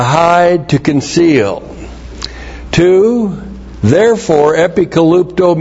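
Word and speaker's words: epikalupto (ἐπικαλύπτω): E-P-I-K-A-L-U-P-T, long O. Epikalupto.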